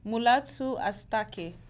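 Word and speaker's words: Mulatu Astatke: Mulatu Astati? How do I pronounce this? I'm sorry. – Mulatu Astati? Can I see? – Yes. – Oh, okay. Mulatu Astake.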